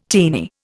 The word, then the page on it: teeny: Indian